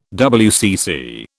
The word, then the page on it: WCC: British English